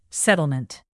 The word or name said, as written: settlement